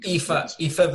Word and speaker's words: Aoife: Aoife Valkyrie. – Aoife, but it's spelled with an A.